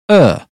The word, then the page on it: bird: British English